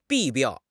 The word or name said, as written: 弊病